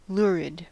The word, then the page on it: lurid: American English